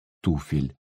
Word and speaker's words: туфель: Вот только в родительном падеже множественного числа мы нередко произносим это слово неправильно. Надо бы запомнить. Туфли — туфель.